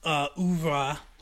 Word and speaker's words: oeuvre: And then the week after that, we'll do issue 4, and then we will have done the entire oeuvre. That's the word I mispronounced last week.